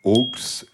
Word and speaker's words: aulx: On dit que parfois, au pluriel, en effet, ce mot se dit aulx et s'écrit AULX. Mais quelle est sa version au singulier?